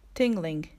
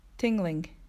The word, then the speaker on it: tingling: L の音は前歯の後ろに舌がついて、ラ、ラと発音するので、 tingling、 tingling となります。